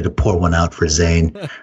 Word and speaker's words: Zayn: and I think it's Jane or One Direction. – Zane. – Is that who it was who left? Zane. Yeah, Zane. That's spelled Z-A-Y-N.